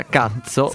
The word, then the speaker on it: Kutso: Ricordiamo che è ovviamente scritto KUTSO, quindi Cuzzo, ma si pronuncia Cazzo.